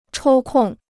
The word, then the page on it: 抽空: chōu kòng